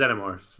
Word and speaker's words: Xenomorph: Xenomorph or Xenomorph? – It's Xenomorph. – Xenomorph, okay. – That's the British way to pronounce it.